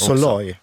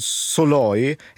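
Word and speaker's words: Szalai: Om boken Kött av David Soloy, eller Salai, men på engelska tror jag han uttalar det Soloy.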